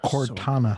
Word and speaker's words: Cortana: There goes my dreams of a Cortina T-Stat again. – Cortana. – So, Cortana. See, can't even pronounce it right.